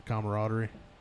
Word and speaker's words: camaraderie: I'm gonna butcher this. The camaraderie. Yep. That sound right? Yeah, yeah, yeah that's right.